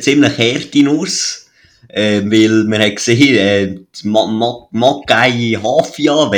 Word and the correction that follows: Maccabi Haifa: wenn ich es richtig ausspreche. – Maccabi äh, Haifa, aber ja. – Maccabi Haifa, so.